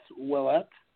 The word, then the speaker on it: Ouellet: Yeah, it's all right, but what my name is, it's hard to pronounce in English, I guess, but it's Ouellet.